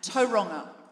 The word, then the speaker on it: Tauranga: I don't want to go on about it, but my birth mother is the head of the Tauranga chapter, and I know I'm saying Tauranga wrongly. How do I say it? Tauranga. Every time. Tauranga. Is that correct? Pretty close, yeah. Tauranga. Thank you.